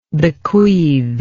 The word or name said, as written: bequeather